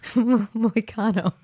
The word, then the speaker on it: Moicano: How do you say his last name? – Moicano. – Okay. I'm just going to say Moicano.